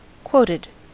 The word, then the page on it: quoted: American English